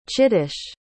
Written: chittesh

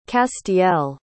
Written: castiel

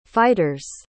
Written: fighters

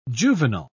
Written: juvenile

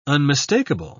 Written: unmistakable

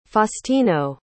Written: faustino

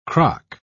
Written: crock

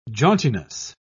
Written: jauntiness

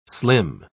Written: slim

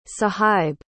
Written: suhaib

